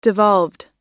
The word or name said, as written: devolved